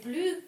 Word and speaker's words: plus: Здесь возможен вариант, здесь не обязательно чтение S. Да. Плюс, Et plus que l'air marin. Здесь факультативное S-чтение. Оно не обязательно. В некоторых, да, в современных вариантах, если вы еще наслушивали современные, там S будут специально произносить.